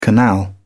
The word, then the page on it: canal: British English